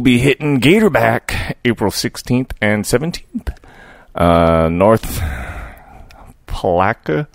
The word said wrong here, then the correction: Palatka: Placa. Palatka, yeah. Palatka, North Palatka, Florida.